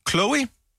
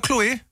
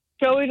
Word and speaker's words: Chloe: Nu skal vi have, jeg tror det udtales Chloe, eller Chloe? – Ja, Chloe, du sagde det rigtigt først.